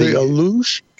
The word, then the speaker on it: Alux: like the I'm not going to be able to pronounce it correctly. The what is it? The Alux and the Shanike. – The Alush and the Chaneke.